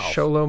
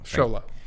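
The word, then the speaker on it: Xolo: Sholo Mardueña. There you go. – Great. I'm loving that name for me to not pronounce correctly. But thank you. – Sholo. There you go. – Sholo.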